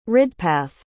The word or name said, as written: ridpath